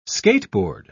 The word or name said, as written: skateboard